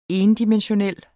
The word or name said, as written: endimensional